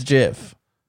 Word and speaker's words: GIF: The guy who created it came out and said it's GIF. – Okay. – So... – I missed that. – But it's because the G stands for graphical, and so he thinks it should be a hard G.